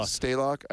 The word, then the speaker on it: Stalock: Stalock.